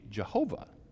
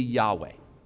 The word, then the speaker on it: Yahweh: And as it was translated into German, it became Yahuwah. And then as the Germans got a hold of it, it became Jehovah. Okay? But probably, best we can tell, the closest pronunciation that we know would be Yahweh.